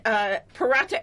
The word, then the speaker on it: paratha: Yes, so we have my Indian paratha expert here, Radhika, and she made them for me the other day.